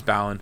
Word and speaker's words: Balan: Is it pronounced Balan? Is it not Balan? – Balan. Sorry, you're right. It is Balan.